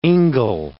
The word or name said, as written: ingle